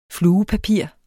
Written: fluepapir

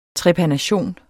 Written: trepanation

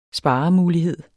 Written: sparemulighed

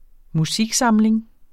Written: musiksamling

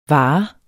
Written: vare

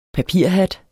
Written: PAPIRHAT

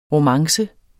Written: romance

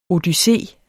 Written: odyssé

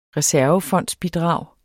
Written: reservefondsbidrag

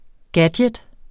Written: gadget

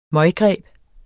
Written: møggreb

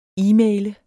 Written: e-maile

